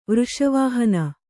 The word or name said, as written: ವೃಷವಾಹನ